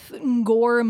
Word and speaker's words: Ngor: I mean, in the case with Ngor Mukui, I hope I'm saying his name right. It's N-G-O-R, Ngor.